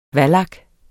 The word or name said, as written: vallak